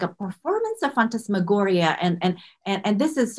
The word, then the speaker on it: phantasmagoria: What's phantasmagoria? Am I pronouncing yeah. – that right? – Yeah, absolutely. Phantasmagoria.